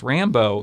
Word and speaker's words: Rambeau: with her sidekick, that pilot, who's got the wonderfully clever name of Maria Rambeau. And she spells it R-A-M-B-E-A-U, but it's pronounced Rambo.